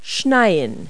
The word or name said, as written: snow